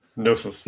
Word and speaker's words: Gnosis: Is it Gnosis? I ask this all the time. Gnosis? Gnosis? – Gnosis. – Gnosis. – Gnosis. Gnosis. I just can't pronounce it. Gnosis. – Silent G.